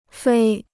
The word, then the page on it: 飞: fēi